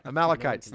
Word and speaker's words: Amalekites: Am I saying that right? Amalekites. – As long as you say it quickly, there it with is. Confidence, man. No one, that's right. Amalekites.